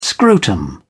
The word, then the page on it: scrotum: British English